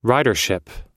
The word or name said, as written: ridership